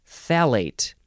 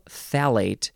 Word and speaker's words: phthalate: D-I-B-U-T-Y-L is the first word, and the second word has two letters that aren't even pronounced. P-H-T-H. A-L-A-T-E. Yeah, it starts with a th-th-th-th sound, but you don't say it. You just say phthalate, dibutyl phthalate.